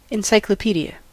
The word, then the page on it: encyclopédie: [yn ɑ̃.si.klɔ.pe.di]; Prononciation sans liaison